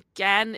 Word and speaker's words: Cannes: Gan is in France, and Gan is how it's pronounced. In French, you do not pronounce the S in Gan.